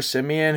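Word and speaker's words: Siemian: Are they going to stick with Simeon or whatever? Is that how you say it? Simeon? – Yeah, Trevor Simeon, who's 13-11, by the way, as a starter for the Broncos.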